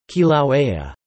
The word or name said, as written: Kilauea